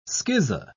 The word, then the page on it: schizo-: British English